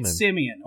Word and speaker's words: Semien: Oh, that's how you say it, Semien.